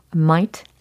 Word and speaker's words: mate: 어, mate라고 발음하지 않더라고요. might.